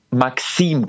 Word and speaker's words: MKSM: Ich weiß, es ist gar nicht so einfach, weil ich werde ja geschrieben MKSM, also einfach nur vier Buchstaben, aber der Name wird ausgesprochen Maxim, genauso wie mein Vorname. Aber das tun ganz viele falsch, deswegen ist es überhaupt nicht schlimm, ich bin es schon gewohnt.